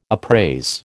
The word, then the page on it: appraise: American English